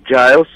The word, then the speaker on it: Giles: Se va a llevar en la parroquia de San Giles, o sea, como se puede pronunciar en español, Giles.